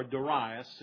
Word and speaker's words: Darius: Now King Darius, or Darius, and you pronounce it either way, and sometimes I may say Darius and sometimes I may say Darius, but I'm talking about the same guy. The correct pronunciation is Darius.